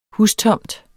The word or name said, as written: hustomt